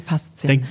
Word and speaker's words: Faszien: ich weiß gar nicht, ob ich es richtig ausspreche, die Faszien. – Ja, die Faszien.